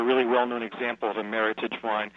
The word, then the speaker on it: Meritage: You say it the American way, Meritage.